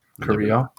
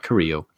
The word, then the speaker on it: Carrillo: Carrillo. – Saying it right. Carrillo.